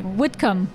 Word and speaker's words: Whitcomb: Did I say that right? – Whitcomb. – The Hotel Whitcomb, yeah.